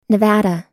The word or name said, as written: nevada